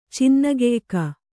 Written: ಚಿನ್ನಗೆಯ್ಕ

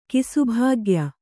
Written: ಕಿಸುಭಾಗ್ಯ

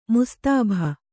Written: ಮುಸ್ತಾಭ

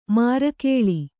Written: ಮಾರಕೇಳಿ

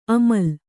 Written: ಅಮ್ಲಿ

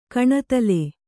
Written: ಕಣತಲೆ

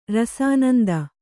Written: ರಸಾನಂದ

